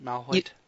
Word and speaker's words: Malhoit: Malhoit? – Yep, that's right.